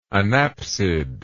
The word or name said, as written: anapsid